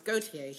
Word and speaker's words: Gauthier: I always pronounce it horribly. It's Gauthier.